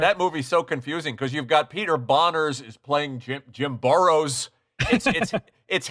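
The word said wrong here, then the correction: Bonerz: Boners, I believe the preferred pronunciation.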